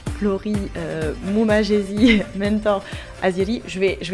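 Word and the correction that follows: Mumajesi: Alors, euh, c'est Elvana Jata, et Flori Mumayesi, parce que le J c'est c'est les Y, Mumayesi, et Mentor Haziri.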